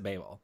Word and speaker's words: Babel: Is it Babel or is it Babel? – They say Babel in the song, they pronounce it Babel. – Okay. – I'm going by what they say.